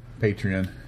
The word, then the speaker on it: Patreon: Patreon? – Patreon.